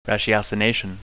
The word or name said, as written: ratiocination